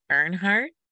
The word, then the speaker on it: Earnhardt: which is the home of Dale Earnhardt.